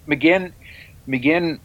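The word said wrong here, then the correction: McGuinn: or what? Geez, I'm going to mispronounce it. – Yep. – McGuin.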